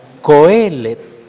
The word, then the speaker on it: Kohelet: Vamos a pasar entonces al estudio del libro de Eclesiastés, que en la Biblia hebrea se llama Kohelet. Ajá. Es que la expresión así, esa H sí se pronuncia como una J así aspirada, en cambio en el español no, por eso decimos Kohelet, podríamos decir en el español.